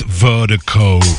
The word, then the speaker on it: Virdiko: And Vertigo is spelled V-I-R-D-I-K-O. Pronounced Vertigo. That's V-I-R-D-I-K-O.